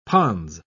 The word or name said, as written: pons